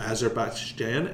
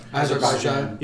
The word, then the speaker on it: Azerbaijan: You've done it in Azerbaijan and a couple, I didn't pronounce that right. – Azerbaijan.